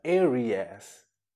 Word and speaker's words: Aries: Se escreve da mesma forma, mas se pronuncia Aries.